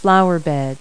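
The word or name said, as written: flower bed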